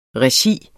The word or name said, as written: regi